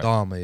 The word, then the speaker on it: Dahmer: I don't know if I'm pronouncing the last name right. It's a bit of a hard name to pronounce with an English accent, but right. Dahmer, yeah? – Yeah.